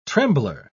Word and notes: trembler: British English

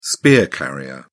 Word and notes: spear carrier: British English

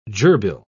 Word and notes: gerbille: British English

